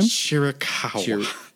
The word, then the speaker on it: Chiricahua: Chiricahua. – Chiricahua.